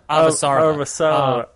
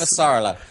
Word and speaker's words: Avasarala: I am completely in love with the two new characters, Bobby and Avarasala. Is that how you pronounce her name? – Avasarala. – Yeah. He only knows how to say that because Avasarala.